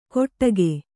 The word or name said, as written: ಕೊಟ್ಟಗೆ